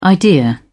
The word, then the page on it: idea: British English